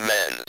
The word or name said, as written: men's